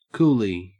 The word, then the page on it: coolie: Australian English